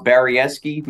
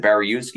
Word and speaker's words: Baryiewski: It's actually pronounced Baryiewski. My whole life, everybody has pronounced it Baryiewski because that's the way it's spelled.